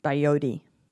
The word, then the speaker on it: Valladolid: Valladolid. Two double L's make a Y sound.